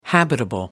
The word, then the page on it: habitable: American English